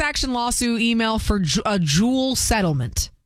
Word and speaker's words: Juul: Do Juul? – Yes.